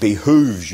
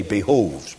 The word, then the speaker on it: behoves: It can also, of course, be translated, I have to be careful how to say this, for you say it behooves you. In English we say behoves, but you understand what I'm saying.